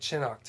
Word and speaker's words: Chinook: It says Chinook, unless I'm pronouncing it wrong. C-H-I-N-O-O-K. That's Chinook, right? – I guess so. – I mean, Citra and Cascade are easy, but that looks like Chinook to me.